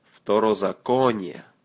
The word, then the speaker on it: Второзаконие: Второзаконие.